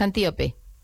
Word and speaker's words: Antíope: de Teseo con Antílope, pero me gustaría no sé, lo he dicho mal el nombre, es Antíope. – Antíope, ¿verdad? – Antíope, no, Antíope, no, que la llamo yo como un animal y no puede ser, Antíope.